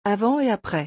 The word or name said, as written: avant et apres